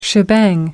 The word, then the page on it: shebang: American English